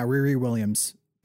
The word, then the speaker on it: Riri Williams: Yeah, Riri Williams.